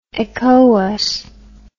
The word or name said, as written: ecowas